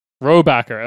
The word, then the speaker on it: Rohrabacher: congressman, Dana Rohrabacher, how would you pronounce that if that was an American name? Because I'm actually... – Rohrabacher, okay, fair enough. Dana Rohrabacher casually argued that Macedonia, quote, is not a country, end quote.